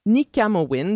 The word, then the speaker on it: Nikamowin: D'ailleurs, il existe une superbe plateforme d'écoute en continu de musique nomade qui s'appelle Nikamowin, donc ça se prononce, ça s'écrit comme ça se prononce, N-I-K-A-M-O-W-I-N.